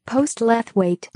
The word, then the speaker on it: Postlethwaite: That's definitely wrong. – That's just pronouncing all the letters. – I could believe Postlethwaite. – Postlethwaite. Because Postlethwaite is what I've always heard. – Yeah, that's always what I've said.